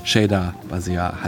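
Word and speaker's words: Shida Bazyar: Und noch als kleine Korrektur hinten raus, ich habe es vorhin selber falsch ausgesprochen, Shida Basia heißt es natürlich.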